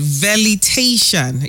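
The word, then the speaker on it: velitation: Valetation.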